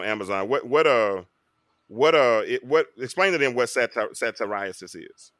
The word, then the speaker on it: satyriasis: It's pronounced satyriasis.